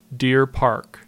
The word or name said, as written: Deer Park